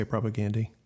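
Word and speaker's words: Propagandhi: And I will continue to say propagandy. – Okay, but it's also how the band says it.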